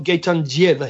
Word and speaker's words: Gaetandzieva: No, hang on. Diljana Gaetandzieva. – No, I've got Gaetandzieva here. – Yeah, well, it's pronounced Gaetandzieva. – Well, okay. Well, maybe we can. My one says G A Y T A. – Yeah, well, this is what that's what she that's how she told me her name is pronounced.